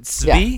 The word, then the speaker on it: Svi: Is that how you pronounce it? Svi? – Yeah.